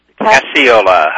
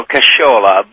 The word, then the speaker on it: Casciola: Casciola. Hi, Cindy. How are you? – I'm good. Casciola. I was close enough. – Well, in Italy, they go Casciola, but here in Los Angeles, I'm lucky enough to get Casciola.